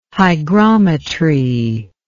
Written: hygrometry

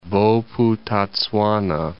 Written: Bophuthatswana

